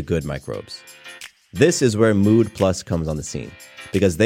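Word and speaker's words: Müd: The company is called Mood Plus, but it's spelled M-U-D with an umlaut over the U.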